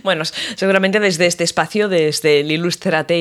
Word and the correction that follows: Ilústrate: Ilústrate, ay, que lo he dicho mal, Ilústrate, ilustrales, ¿eh?